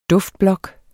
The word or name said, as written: duftblok